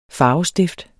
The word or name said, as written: farvestift